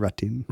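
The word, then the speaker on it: Rattín: But the reason is that Ratten was, and I hope I'm pronouncing that right, Ratin. – That sure, would be the French way. French. What is it, R-A-T-I-N? – R-A-T-T-I-N. – Yeah, it's probably Ratin.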